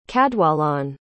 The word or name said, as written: cadwallon